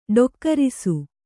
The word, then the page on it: ಡೊಕ್ಕರಿಸು: ḍokkarisu